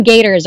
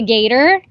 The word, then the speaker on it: gaiter: And he calls it a gator. – Yeah. – Neck. Gator neck. Scarf. Gator neck warmer. – Is it like the same exact thing? Same thing? – But it's spelled G-A-I-T-E-R.